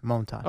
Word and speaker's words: Montas: Frankie Montas. – Montas. – You're going Montas? – Moan. – Moan. – Yeah, baseball toss. Reference, Moan toss.